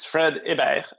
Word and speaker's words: Fred Hibbert: It's Fred Hibbert, but I do accept the English pronunciation as well.